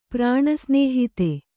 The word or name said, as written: ಪ್ರಾಣಸ್ನೇಹಿತ